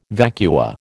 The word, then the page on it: vacua: American English